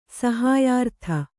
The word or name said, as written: ಸಹಾಯಾರ್ಥ